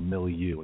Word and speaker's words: milieu: It's really the term is actually called MILIEU, and it's spelled M-I-L-I-E-U.